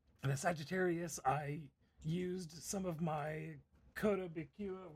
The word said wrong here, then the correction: Kotobukiya: So here, Kotobukiya.